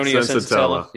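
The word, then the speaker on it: Senzatela: Sensatella.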